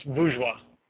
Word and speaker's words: bourgeois: Most of Marx's work talks about the conflict between the, I'm totally not going to pronounce this right, burgios, which basically is a word for the ruling elite and owners of the main, what was that? – It's bourgeois. – Bourgeois. Okay. Thank you, Paradigm.